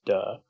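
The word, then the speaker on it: Da (德): So then this idea of Da, unfortunately it's pronounced Da,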